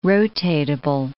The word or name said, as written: rotatable